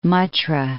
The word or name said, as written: Muttra